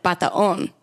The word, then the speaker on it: Batoon: I say Batoon, but I think like the dialect is like Bataon.